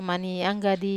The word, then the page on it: mani anggadi: Wamesa